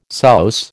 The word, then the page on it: souse: American English